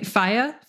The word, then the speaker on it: FIYAH: Uh, these are a spinoff of FIYAH Magazine, pronounced like fire without the R. So, uh, Fi, Fi, Fi I said it right, FIYAH,